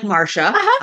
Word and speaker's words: Marcia: No one had a name like Marcia.